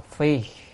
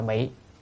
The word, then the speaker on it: phi: Đây Tịnh Độ Đại Kinh Giả Diễn Nghĩa đây à, đây là dịch giả Tịnh Tấn thì à, ở đây đoạn này chữ này đọc là phi, đem quần áo mặc vào người đó, không đọc là bị đó,